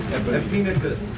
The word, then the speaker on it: Epaenetus: It is Epaenetus. – Everybody hear that? Epaenetus. Everybody say it. – Epaenetus. – Once more. – Epaenetus.